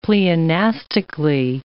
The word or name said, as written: pleonastically